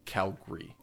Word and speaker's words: Calgary: So if you are like anywhere else in Canada, you'd call it Calgary, but Calgarians call it Calgary or Calgary. So probably if you went with the local source, Calgary is the correct pronunciation.